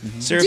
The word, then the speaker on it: CeraVe: You consistently use CeraVe. Is that how you pronounce it? – CeraVe.